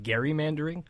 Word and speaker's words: gerrymandering: It's actually better pronounced gerrymandering because the man's name was Elbridge Gerry.